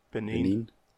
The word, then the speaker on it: Benin: I'd probably taken some of the sites of what is now modern day Benin. Is that how you pronounce it? – Benin. – Is that what it was? – Yeah, that's where the kingdom of Dahomey, that's what it used to be.